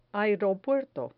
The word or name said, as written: aeropuerto